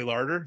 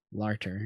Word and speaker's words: Larter: Larter.